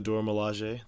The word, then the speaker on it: Dora Milaje: It's a pronunciation. It is the Dora Milaje.